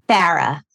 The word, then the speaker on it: Farah: How do I say your name? – Farah. – Farah, like a Michigan era. – Or Boston.